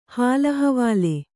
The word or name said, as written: ಹಾಲಹವಾಲೆ